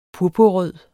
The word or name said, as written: purpurrød